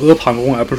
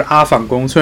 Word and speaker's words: 阿房宫: 我也希望大家能够把阿房宫读成阿房宫，而不是阿房宫。